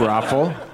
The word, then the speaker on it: ROFL: Somebody in the audience knew how to pronounce it. – Yeah, Raffle. – That's, I thought it was R-O-F-L.